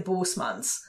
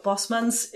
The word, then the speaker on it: Bosmans: Because my German ear wants to say Bosmans. Mm-hmm. – So Bosmans is a, from an official Dutch person.